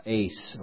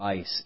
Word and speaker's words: eis: That's the word ace or ice. E-I-S is how we'd spell it in English.